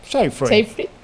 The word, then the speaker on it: Seyfried: Sci-Fi. Como Sci-Fi, como el canal, pues Sci-Fi.